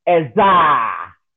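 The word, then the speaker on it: Ezer (עזר): Helpmeet in the Hebrew is ezer. Ezer. E-Z-E-R. Let me spell it for you. E-Z-E-R. Ezer. E-Z-E-R. Helpmeet. Ezer. E-Z-E-R.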